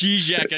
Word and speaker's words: Žižek: Zizek, I should say. So you didn't even say it right.